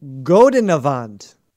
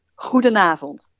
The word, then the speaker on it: goedenavond: Godenavond. – Oh, man, I have no idea what you're saying. Oh, yes, I know. It takes a lot of imagination, because the real pronunciation is goedenavond.